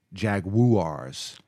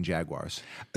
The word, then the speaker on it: Jaguars: Yes, and you have to pronounce the U in Jaguars. – So, no, I like to say Jaguar. – Yeah, Jaguar. That's also acceptable. The judges will accept it.